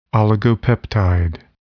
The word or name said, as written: oligopeptide